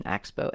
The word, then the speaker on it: Axboe: I was saying maybe the pronunciation, Axbo, AXBOE is how you spell it.